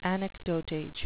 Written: anecdotage

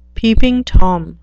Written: peeping tom

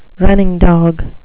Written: running dog

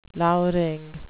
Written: lowering

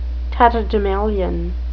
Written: tatterdemalion

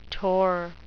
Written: tor

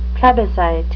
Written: plebiscite

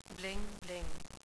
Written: bling-bling

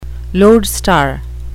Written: lodestar

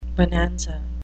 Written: bonanza

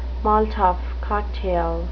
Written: molotov cocktail

